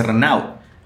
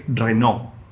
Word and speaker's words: Renault: Y, por ejemplo, la marca Renault, Renault, no se dice Renault, es Renault.